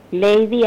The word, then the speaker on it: Lady: Lady, así como se pronuncia, como se dice, Lady.